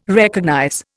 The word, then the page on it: recognize: Indian